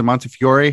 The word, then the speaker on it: Montefiore: Is it Montefiore? How do you pronounce it? – We say Montefiore. I know in America, some people say Montefiore. We say Montefiore. Okay.